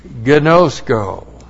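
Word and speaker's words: Gnosko (γινώσκω): And that phrase, the one who has come to know God, that is the verb gnosko. G G-I-N long O-S-K long O.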